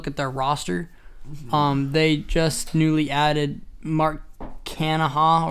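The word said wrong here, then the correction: Canha: or whatever you want to say his name. – Mark Canna.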